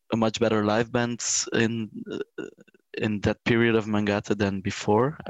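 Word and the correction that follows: Mångata: So I think it's actually Mungata in Swedish.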